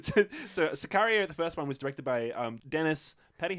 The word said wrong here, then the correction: Denis Villeneuve: How do you pronounce that? – Denis Villeneuve.